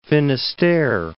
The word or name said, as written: Finisterre, Cape